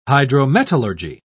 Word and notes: hydrometallurgy: British English

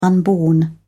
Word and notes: unborn: British English

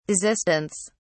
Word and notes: desistance: British English

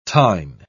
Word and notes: tine: British English

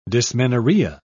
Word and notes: dysmenorrhea: British English

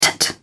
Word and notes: tut: British English

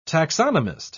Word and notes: taxonomist: British English

